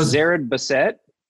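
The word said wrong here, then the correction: Zered Bassett: It's not Zara Bassett. Bassett, isn't it? – Yeah. Zara Bassett.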